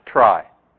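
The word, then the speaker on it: trie: So, to start, we're going to have a misspelling of tree. We pronounce it try.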